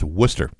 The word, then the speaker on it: Worcester: on the 1300 block of Worcester Street. I believe it's pronounced Worcester.